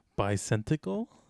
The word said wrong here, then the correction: Bicentennial: Bicentinel. Bicentennial, man. – Oh, yeah, Bicentennial.